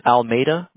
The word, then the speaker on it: Almeida: My last name is Almeida. It's Portuguese. You don't have to pronounce it right. That's okay. Everybody doesn't get it right, but it's in my emails. The Almeida.